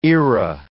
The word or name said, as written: era